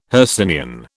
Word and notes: Hercynian: British English

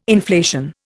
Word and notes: inflation: Indian

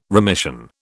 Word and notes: remission: British English

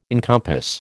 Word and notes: encompass: American English

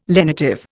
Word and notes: lenitive: Indian

